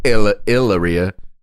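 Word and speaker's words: Hilaria: So Alec Baldwin, his current wife is named Hillaria, or as she pronounces it, Hillaria, no H sound there.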